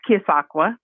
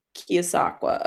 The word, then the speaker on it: Keosauqua: I hope I pronounced, is it Keosauqua? Did I say that correctly? – You did pretty well. It's Keosauqua. – Keosauqua.